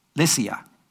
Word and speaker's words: Lycia: And this 4th century saint was also the Greek bishop of Myra in Lucia. Lycia. I said that wrong.